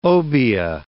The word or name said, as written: obeah